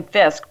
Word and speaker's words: Fiske: I, I did pronounce that wrong, so it's Kim Fiske, but her last name is spelled F I S K E dot com.